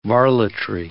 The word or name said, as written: varletry